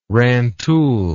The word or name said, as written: rantoul